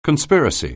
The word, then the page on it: conspiracy: anglais